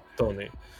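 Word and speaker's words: Toney: Вот мне первый вопрос, да, вот у всех самый популярный игрок при старте, я как сам помню, это был э, Иван Тонней. – Да. Если я правильно а, произношу. Тони, Тони, по-моему, да. да. Тони.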